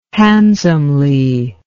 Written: handsomely

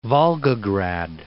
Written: Volgograd

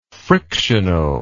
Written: frictionally

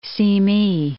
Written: Simi Valley

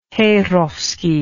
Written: heyrovsky

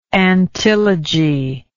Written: antilogy

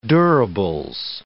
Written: durables

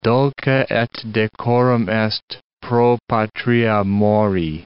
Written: dulce et decorum est pro patria mori

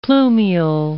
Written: plumule

